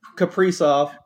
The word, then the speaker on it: Kaprizov: I always pronounce his name wrong. Case Kasparov. – Kaprizov. – Kaprizov. I put the S before the P.